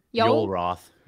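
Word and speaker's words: Yoel Roth: How do you say his name? – Yul Roth.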